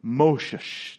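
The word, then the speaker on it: Moshe: You can hear, friends, the cascading of water off the basket into the Nile as you say his name. Moshesh. Doesn't that just sound like whoosh? No?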